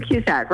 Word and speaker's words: Cusack: Yes, Sue Cusack, right.